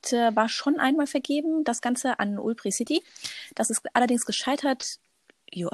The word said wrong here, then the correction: Ubitricity: Ubitricity, I'm sorry for that.